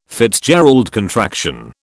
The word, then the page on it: FitzGerald contraction: British English